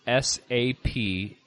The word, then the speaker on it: Saperavi: And I'm going to try to say it correctly. It's called Saperavi. So it's Saperavi.